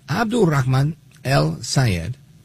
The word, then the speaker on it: Abdulrahman El-Sayed: And I say it like that again, at his request. So, Abdulrahman El-Sayed, he says he's not moderating his position on Israel.